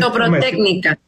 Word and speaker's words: ortoprotécnica: Acá para hablar con directora de ortoprotedia, ¿no? Tengo me decir. – Ortoprotécnica. – Protécnica, a ver si lo digo bien.